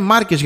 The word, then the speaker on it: Μάρκες: Νομίζω ο δικός μας είναι Μάρκες, γιατί στους Πορτογάλους νομίζω τα τονίζουν αλλιώς.